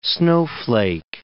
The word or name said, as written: snowflake